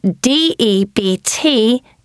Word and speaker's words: debt: And D E B T is debt.